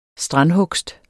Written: STRANDHUGST

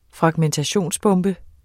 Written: fragmentationsbombe

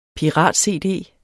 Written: pirat-cd

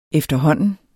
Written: efterhånden

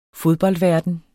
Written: fodboldverden

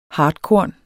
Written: hartkorn

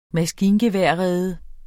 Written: maskingeværrede

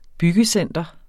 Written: byggecenter

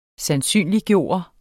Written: sandsynliggøre

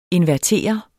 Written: INVERTERES

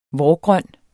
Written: vårgrøn

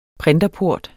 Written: printerport

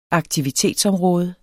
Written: aktivitetsområde

